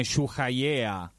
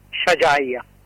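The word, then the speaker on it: Shujaiya: Ascoltami, ma il quartiere che ieri è stato preso di mira a Gaza City, non so se lo pronuncio bene, Shuhayea. – Shajaya.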